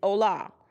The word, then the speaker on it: hola: So on this first card, if you can see, this card is in Spanish, and it is hola. Hola, the H is silent, and hola means hello in Spanish.